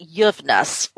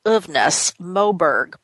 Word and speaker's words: Uvnäs Moberg: Uh, her name is I believe she pronounces it Yuvness or Uvnäs Moberg.